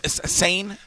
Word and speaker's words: Seine: Seine? How do I say it? – Correct. Okay. Seine. Fishing Seine. Like the Seine River in France.